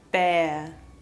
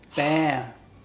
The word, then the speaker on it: bear: Bear. – Good, bear. – Thank goodness. – Good, we don't pronounce the R, bear.